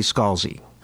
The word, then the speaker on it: Scalzi: I'm assuming that's correct, but I'm never sure if it's supposed to be Salzi or Schalzi or Scalzi. – In Italian, it would be Scalzi.